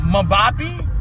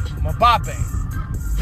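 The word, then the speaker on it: Mbappé: But I hope it's Kylian Mbappe. Or Mbappe, my bad. Mbappe.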